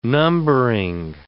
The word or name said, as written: numbering